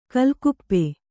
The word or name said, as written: ಕಾಲ್ಗಾಪು